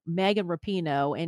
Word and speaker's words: Rapinoe: I always say her name wrong. Megan Rapinoe.